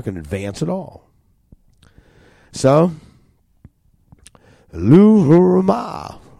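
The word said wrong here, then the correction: Lo-ruhamah: I didn't say it right again. It's Loruhama. Loruhama.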